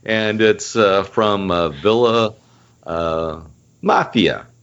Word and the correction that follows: Maffei: Maffei. M-A-F-F-E-I.